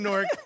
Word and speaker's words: Newark: I'm born and raised in Newark, and this is how it's supposed to be pronounced, N-O-R-K.